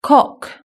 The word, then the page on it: cock: British English